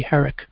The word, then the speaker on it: Van Herck: Van Herck, however you pronounce his name, H-E-R-C-K, Herrick.